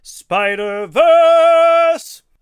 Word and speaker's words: Spider-Verse: I'm sorry to say you mispronounced a very certain word that I know Ryan and Lorraine would, you know. It's actually pronounced Spider-Verse. That's the correct pronunciation of that.